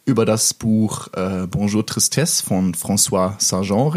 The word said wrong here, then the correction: Sagan: reden. Jetzt habe ich es falsch gesagt. – Ne? Das ist falsch gesagt. Sollen wir mal? Ja. Franz. wir wollen. Wir wollen über Bonjour Tristesse von François Sagan reden. War das jetzt richtig? Ich, ich weiß, François Sagan.